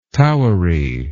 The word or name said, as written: towery